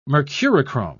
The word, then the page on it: mercurochrome: British English